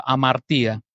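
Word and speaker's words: amartía (ἁμαρτία): ese término es la palabra en griego que se escribe amartía, se pronuncia amartía, errar al blanco.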